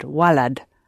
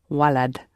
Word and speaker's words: walad: If you want to say boy, you have to use a different word, walad. Walad. – Almost like lad. – It is actually, yes. – Way lad. – Walad. – Walad. Walad. But compressed. – Arabic generally is, the pronunciation generally is more compressed. Don't tend to draw out the vowel sounds like we do in English sometimes. So it's quite compressed.